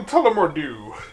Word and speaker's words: Tullamore Dew: Oh, we got a little Tullamore Dew to continue the bad accents. – No, that's how you say it.